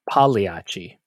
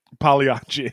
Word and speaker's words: Pagliacci: I believe it is pronounced Pagliacci. – Pagliacci, yeah.